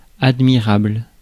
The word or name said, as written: worthy